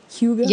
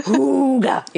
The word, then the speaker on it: hygge: hygge. Yes. I, I, think you're supposed to put the emphasis on the first syllable it says. So then I find myself going like who Hooga.